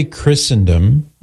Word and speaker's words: Christendom: So we say Christendom with a silent T because some words in English have a silent T, like Christmas.